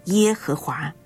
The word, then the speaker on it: 耶和华: 所以对他的疑问，神没有发怒，并且加以回应，我是自有拥有的，耶和华是我的名直到永远，这也是我的纪念直到万代。我是自有拥有，希伯来文的发音就是耶和华，意思是我是我所是，或者是我将是我所是。